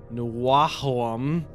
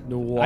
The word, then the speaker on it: NWOAHM: The new wave of American heavy metal scene, commonly abbreviated as NWOAHM, so Nwahom. – Wait, spell it again? – NWOAHM. – What? – Nwahom.